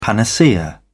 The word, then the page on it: panacea: British English